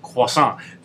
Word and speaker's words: croissant: And then, but so he actually, you know, Gordon Ramsay trained as a French chef. So he knows how to say things properly. So he goes, croissant. This chick almost had a heart attack. She's like, oh, he said it right.